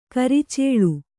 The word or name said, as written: ಕುರುಚಲು